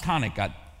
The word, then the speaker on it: Hanukkah: And remember, if it's got a C in front of it, it's only the Hebrew, but it's still pronounced Hanukkah. The C is silent, all right?